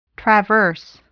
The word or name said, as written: traverse